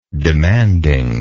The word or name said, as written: demanding